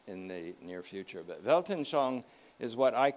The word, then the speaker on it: Weltanschauung: It's called Weltanschauung, W-E-L-T-A-N. One word, S-H-A-U-U-N-G, pronounced Weltanschauung.